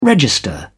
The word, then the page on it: register: British English